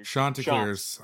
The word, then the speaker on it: Chanticleers: The NCAA gets their life together and they expand the playoffs and the Chanticleers. Did I say that right? – Chanticleers. – That sounds way fancier than how I said it.